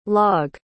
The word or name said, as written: logge